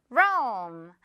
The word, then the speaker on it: Rome: Rome.